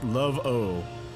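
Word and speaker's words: Laveau: Doug would like to correct you and say the name is pronounced Love-O.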